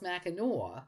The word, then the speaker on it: Mackinac: I mean, it's we know it's pronounced Mackinac, but why did they dump the ack from the Native American pronunciation?